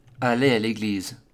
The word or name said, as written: Aller a leglise